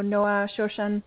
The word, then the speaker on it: Noah Shoshan: So Noah Shoshan, people pronounce it differently depending on their language, but that was perfectly fine.